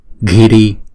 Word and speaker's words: ghiri: Chữ G mà đứng một mình á thì chúng ta đọc là g, còn chữ G mà có chữ H kèm theo á thì chúng ta đọc là g g. Cho nên chữ này chúng ta đọc là ghi ghiri ghiri ghiri ghiri sarva samanta akarshani akarshani.